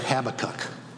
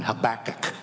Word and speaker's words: Habakkuk: I have to tell you, I was born and raised to pronounce that Habakkuk. And that's a way lot easier on my lips and tongue than Habakkuk. But I'm doing that out of deference to my superiors and my betters.